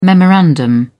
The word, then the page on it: memorandum: British English